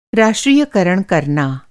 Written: राष्ट्रीयकरण

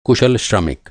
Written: श्रमिक

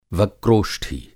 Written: वक्रोष्ठी

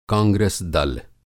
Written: कांग्रस दल

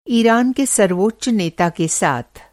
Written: सर्वोच्च

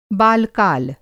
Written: बालकाल

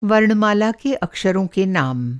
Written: अक्षर

